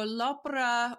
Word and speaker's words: Opera: But what is in, is it L'Opera? Am I pronouncing it right? What is in Scappi's cookbook? – So L'Opera or opera is, it means the opera or the works of Bartolomeo Scappi.